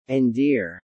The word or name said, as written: endear